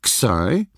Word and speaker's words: Xi: There's the Greek Xi, pronounced like our eggs, but looking nothing like it.